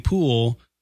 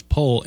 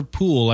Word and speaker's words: pool: Yeah, I, vo- verbally I was saying pool. Uh, that's how I was pronouncing it, but I was saying like it was pole anyway. Sorry for the confusion if I did confuse anyone. It's silver pool, like you have swim in.